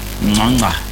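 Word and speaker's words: Ngcongca: Before Anele Ngonga, why am I even saying Ngonga? They have a certain way that they say it in Zulu. Nganga, that is how they say it.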